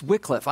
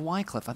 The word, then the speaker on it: Wycliffe: But John, we thank you so much for your ministry, and it's Wycliffe. I thought it was Wycliffe.